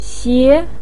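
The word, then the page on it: xie: tone 2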